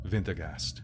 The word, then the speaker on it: Wintergast: But you say it more German. Wintergast.